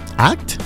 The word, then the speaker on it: at: ¿At? – Ajá. At. A-T. Ate. – Ate. – At. – At. – Ajá. En inglés el arroba se pronuncia at, que significa literalmente en o junto a.